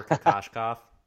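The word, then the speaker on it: Kokoškov: The coaching name that I always think of, and maybe it's just because I watch the Jazz so much, is Igor Kokoshkov. And I don't know of him more if I could pronounce his name, Igor Kokoshkov. – Hey, that was perfect. You got it.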